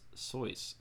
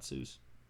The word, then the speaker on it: Seuss: Uh, Seuss is the mother's maiden name, and it is pronounced Sois. Sauce? S O I S E? – Sois. – Sois, not Seuss. Tell me that wasn't a good one.